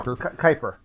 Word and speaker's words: Kuiper: I thought it might have been, like, the debris field, as in, like something like the Kuiper Belt? The Kuiper Belt. The Kuiper Belt. What is it? – Kuiper.